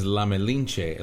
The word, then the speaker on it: La Malinche: The name of the song is La Malinche.